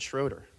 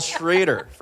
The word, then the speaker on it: Schrader: Paul Sh- Schrader, I almost said Schroeder like Rachel Schroeder, because it's spelled that way, but Paul Schrader from IRA.